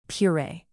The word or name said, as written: puree